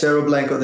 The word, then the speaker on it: Cerro Blanco: And speaking of that, so let's turn now to, is it Cerro Blanco? How would you pronounce this? – Yes, Cerro Blanco, the Cerro Blanco Gold Project in southern Guatemala.